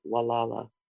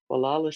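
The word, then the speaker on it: Gualala: My first preferred site is the Bluff Trail in downtown Wallala, Mendocino County, California. We pronounce it Wallala. Wallala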